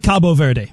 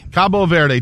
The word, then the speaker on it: Cabo Verde: I like Cabo Verde. – Cabo Verde.